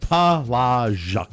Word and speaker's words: Palazic: Palazic. – That's the one. – Who was the very... Zha, a hard thing to write as a pronouncer. – It's not a normal sound. You don't hear that normally.